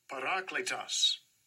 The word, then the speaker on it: Parakletos: Parakletos. Parakletos. Parakletos. Parakletos. Or parakletos. Parakletos. That's I, I stress the lay there.